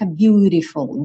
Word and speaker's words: beautiful: Então, originalmente seria beautiful, porque a gente tem uma letra T, mas com uma pronúncia mais americana fica beautiful.